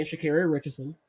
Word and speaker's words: Sha'Carri Richardson: Sha'Carri Richardson, and that's how you pronounce her name, Sha'Carri Richardson.